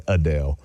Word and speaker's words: Adel: So it's between Valdosta and another town called Adel, which it's so funny asking people to pronounce it too. They'll say Adel.